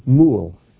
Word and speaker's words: mul: You see, the very word for circumcised is the word mul. You could spell it M-U-L. That word mul is found 36 times in the Old Testament in Scripture, in Hebrew. 36 times. But that exact same word, spelled exactly the same way, can also be pronounced mol. M-O-L.